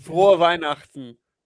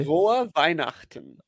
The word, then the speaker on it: Frohe Weihnachten: Frohe Weihnachten. – Oh, pinch, otra vez. Para la gente. – Frohe Weihnachten.